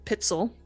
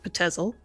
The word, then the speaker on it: Pitzel: Emmeline came to his attention because one of his accomplices, Benjamin Pitzel, which is how it's pronounced, not Patezel, apparently, even though it's spelled Patezel.